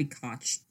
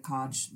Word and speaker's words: Koch: K-O-C-H. It might be Koch. – It's both, actually. Some people pronounce it Koch, but the Koch brothers pronounce it Koch.